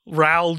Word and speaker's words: Raoul: Raoul Duke, who's the main character in Fear and Loathing in Las Vegas.